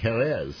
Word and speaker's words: Jerez: Inspired by, I'm going to say it right, Jerez, right? – Did I say it right? – Yeah.